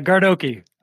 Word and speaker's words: Gardoqui: I totally forgot to ask you how to pronounce your last name, and I'm going to butcher it, but I'm going to try. Okay. Yeah. Dan Gardoki. How'd I do? – Gardoki. Gardoki. Just look okay. Okie dokie.